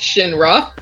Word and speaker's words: Shinra: That was Shira. – I'm pretty sure it's Shinra.